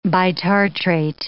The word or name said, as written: bitartrate